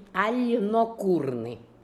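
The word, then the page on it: Alnokoorian: Russian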